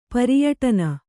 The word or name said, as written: ಪರಿಯಟನ